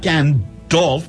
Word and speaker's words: Gandalf: And so, and I said, yes, how do you pronounce G-A-N? D-A-L-F. And he says, Gandalf. – Gandalf. – I said, Gandalf? He says, yes. Like half. Like, you know, half a sixpence.